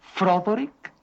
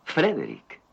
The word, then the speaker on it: Frederick: ¿Dice usted también Froderick? No, Frederick. ¿Y por qué no es Froderick Frankenstein?